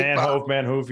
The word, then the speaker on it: Manhoof: Am I saying it right? He's a kick. – Manhoof. Yeah, I think you're close.